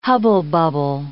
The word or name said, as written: hubble-bubble